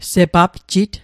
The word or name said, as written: sepapjit